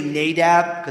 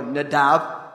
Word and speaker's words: Nadab: But it would probably be more Nadab, because in Hebrew it's on the second syllable, one of the differences between the languages.